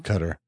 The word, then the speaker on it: Qatar: This place is called Qatar. I have heard it say cutter before, pronounced cutter.